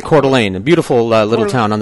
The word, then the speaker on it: Coeur d'Alene: It's pronounced Coeur d'Alene. – Coeur d'Alene? – Yes, it's French.